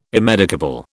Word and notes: immedicable: British English

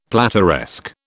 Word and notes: plateresque: British English

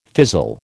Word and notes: fizzle: American English

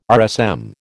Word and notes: RSM: American English

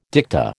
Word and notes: dicta: American English

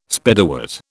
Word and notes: Spiderwort: British English

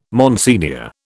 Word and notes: Monsignor: British English

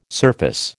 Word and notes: surface: American English

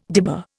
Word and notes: dibber: Indian

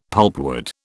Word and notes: pulpwood: British English

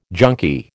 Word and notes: junkie: American English

